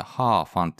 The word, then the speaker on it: 河粉: えー、皮っていうのはあの、三髄に可能のかって書く、えー、難しい漢字の方の、まあ、川なんですけれど、川に粉、うん、これでハーファンって読むんですけれども、この紹介をしたいと思います。 – はあ、で、はい。そう、ハーファン。